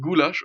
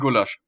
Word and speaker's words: Gulasch: Es das heißt eigentlich Gulasch und alle Leute sagen Gulasch, als würde man das mit Doppel-L schreiben. – Achso, das heißt ein Gulasch, meinst du? Ja. Mit der Betonung auf dem U.